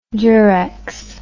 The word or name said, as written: durex